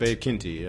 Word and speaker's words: Quinte: Is that how you say it? Bay of Quinte? – Bay of Quinte, yeah. Bay of Quinte. – Bay of Quinte, okay.